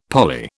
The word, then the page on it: parlay: British English